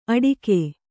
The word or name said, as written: ಅಡಿಕೆ